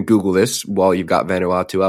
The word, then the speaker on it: Vanuatu: I believe that's pronounced Vanuatu, actually.